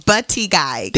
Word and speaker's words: Buttigieg: Buttigieg.